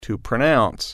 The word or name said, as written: pronounce